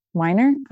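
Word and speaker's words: Wiener: You know, the bills that Scott Warner keeps, Weiner, I always say his name wrong.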